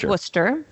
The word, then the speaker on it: Worcester: Because it's Worcester.